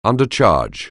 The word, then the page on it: undercharge: anglais